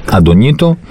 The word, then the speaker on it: Αντωνίτο: Μαουρίσιο, πρώην, πρώην ΠΑΟΚ 32 και ο αγαπημένος μου Αντωνίτο και μην ε, γελάτε, ας πούμε, αυτή είναι η σωστή προφορά του ονόματος Αντωνίτο, δεξιό μπακ 33.